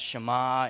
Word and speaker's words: Shema: They have like this strange name for it because it's actually the word here in verse 4 in Hebrew is pronounced Shema, and that doesn't really mean much to anyone.